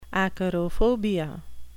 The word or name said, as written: acarophobia